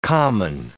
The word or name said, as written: common